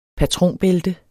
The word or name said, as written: patronbælte